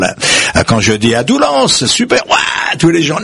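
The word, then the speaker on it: Doullens: Alors c'est Doulans, ça s'écrit, mais on prononce, paraît-il, là-bas. – Doulans.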